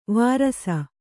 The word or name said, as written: ವರಿಸು